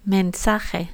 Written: mensaje

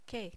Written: que

